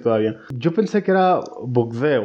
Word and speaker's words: Bourdain: Entonces lo revisé en Google para ver cómo se pronunciaba. Y efectivamente es Bourdain. Y yo, pues bueno, entonces lo estaba pronunciando perfectamente. – Porque en las noticias francesas es Bourdain. pero en ah, CNN es Bourdain.